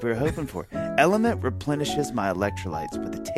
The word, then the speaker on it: LMNT: Elementant. – It's not French, right? Actually. It's the letters L-M-N-T, and it's pronounced Element.